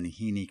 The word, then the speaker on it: Meneghini: because she sang under the name Maria Meneghini Callas, Callas in those days, her husband's name but the Mexican Spanish pronunciation was Menjini Calles, and I just thought her voice was extraordinarily beautiful.